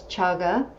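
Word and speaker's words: cāga: There's another aspect of generosity that's called chaga, and it's C-A-G-A. In Pali, C is always pronounced C-H, so it's chaga, and there should be an accent over the first A, a straight line over it, the same as with dana.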